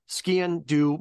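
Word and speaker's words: sgian-dubh: Now, the difference, I think you said it right, but just for the listeners, the way you pronounce it's basically skindo means black knife. The way to remember it is like, say like skiing, but skin do. So you're kind of pronouncing the SG ski in, do,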